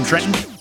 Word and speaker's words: Trenton: There is a point where Walker Scoble, as Percy Jackson, says they haven't even made it to Trenton. – My New Jersey soul. You can't pronounce the second T in Trenton.